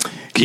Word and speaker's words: Quixote: That's how you say it. – Quixote. Yeah, it took me a long time to figure that out.